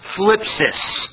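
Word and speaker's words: phlipsis (θλῖψις): It is the Greek word pronounced phlipsis. It makes you sound like you're lisping when you say it.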